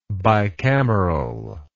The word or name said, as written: bicamerally